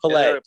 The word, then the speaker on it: Pele: P-E-L-E just passed away. – Yeah. – How would you pronounce that? – I call him Pele.